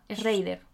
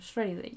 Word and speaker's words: Schrader: Schrader. – Schrader.